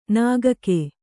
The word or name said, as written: ನುಗ್ಗು